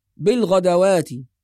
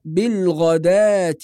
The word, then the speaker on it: بالغداة: كلمة بالغداة بعض الناس يقرأها بالغدوات، بسبب وجود الواو في الرسم، والبعض يقرأ بالغدوة، وكلاهما خطأ. والصواب في رواية حفص أن نقرأ بالغدات، فننطق ألف بعد الدال، وأما الواو لا تنطق، وهناك ألف موجودة في الرسم فوق الواو مباشرة، فتلغى الواو وننطق مكانها ألف بالغدات والعشي بهذه الطريقة.